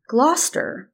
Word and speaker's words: Gloucester: And then she said she lives in Massachusetts and says that they pronounce their city Gloucester.